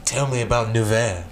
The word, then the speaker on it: Nevers: And how do you say it? – Nevers. – Nevers. – Nevers. – Yeah. Get close.